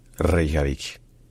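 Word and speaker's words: Reykjavík: Donc à l'origine, en islandais, ça se dit Reykjavik. Reykjavik. Reykjavik.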